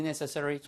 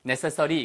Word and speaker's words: necessary: 독감 예방주사를 get, 맞는 것을 every year, 매년 하는 것이 Is it really necessary? 정말 필요해? 어렵지 않습니다. 발음도요. Necessary, 이게 아니고 necessary, necessary처럼 들려요.